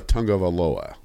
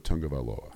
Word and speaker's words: Tagovailoa: Tua Tagovailoa. – That kind of rolled off the tongue. – Tua Tagovailoa.